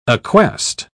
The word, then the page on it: acquest: British English